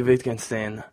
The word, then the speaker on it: Wittgenstein: Ludwig Wittgenstein.